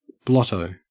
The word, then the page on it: blotto: Australian English